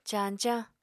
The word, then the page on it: cañcañ: Apatani